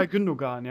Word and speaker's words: İlkay Gündoğan: İlkay Gündoğan und, habe ich den richtig ausgesprochen? Keine Ahnung. – İlkay Gündoğan, ja.